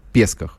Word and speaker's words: Песках: А я всем рекомендую зайти сегодня на а, сайт комсомолки и прочитать а, новый репортаж Коца о событиях Песках. Правильно я же ударение ставлю, не ошибаюсь. Да, все верно, все верно.